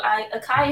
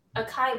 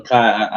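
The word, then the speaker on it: açaí: Like try pronouncing it the way it should be said. That's the way I see it. – What I like to say, like, I feel long, so Akai is cuter, and I like it.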